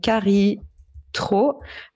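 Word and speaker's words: Kari Traa: Euh, Carrie Tro. Alors, c'est Carrie et Tro, c'est T-R-A-A. – Ah oui, vous prononcez comme ça.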